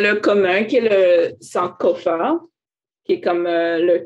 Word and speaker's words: Sankofa: Sankofa, S-A-N-K-O-F-A.